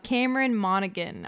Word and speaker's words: Cameron Monaghan: Cameron Monaghan, I'm pretty sure is how you pronounce his name. – Yes.